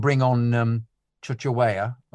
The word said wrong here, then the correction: Chukwuemeka: or whatever his name is? I've forgotten. Sorry, forgive me the pronunciation. Chukwuemeka. Sorry, Chukwuemeka.